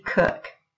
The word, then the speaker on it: Koch: The title of it is How I Am Smart by Kathy Cook. Now, her name is spelled Kathy with a K, and then Cook is spelled K-O-C-H.